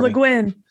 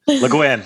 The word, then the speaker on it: Le Guin: Oh, Le Guin. – Le Guin.